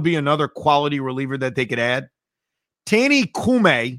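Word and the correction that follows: Coulombe: I think it's Coulomb.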